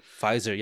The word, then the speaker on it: Pfizer: Pfizer, yeah, Pfizer. – The P is silent. I don't know why, but it's, I think it's German or some shit.